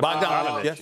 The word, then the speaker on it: Bogdanovich: Ingles and Bogdanovich, I hope I said it right. – Yep. Bogdanovich, yes.